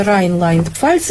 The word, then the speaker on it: Рейнланд: в земле Райнланд-Пфальц или Рейнланд-Пфальц, как правильно говорить? – Ну, наверное, по-русски Рейнланд. – Рейнланд.